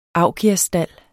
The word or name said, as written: augiasstald